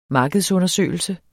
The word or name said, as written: markedsundersøgelse